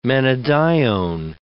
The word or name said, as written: menadione